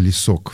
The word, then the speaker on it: Лесок: Да, Татьяна, наверное, Лесок все-таки на последний слог ударения, если что, поправьте.